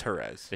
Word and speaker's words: Jerez: I was there. It was her. It's Jerez.